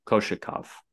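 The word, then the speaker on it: Kochetkov: Same with Ranta and Kokechkov. How do you say his name? – Koshikov. – Koshikov.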